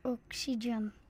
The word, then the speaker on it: Oxygen: Dat is bij Oxykids. Zeg ik dat goed? – Oxygen. – Oxygen. Oh, hij zegt Oxykids. Oxygen, dat is van...